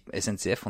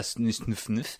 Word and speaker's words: SNCF: comme SNCF, on dit SNCF, on dit pas snuf